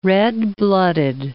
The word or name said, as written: red-blooded